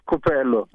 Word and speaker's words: Kupelo: It's Kupelo.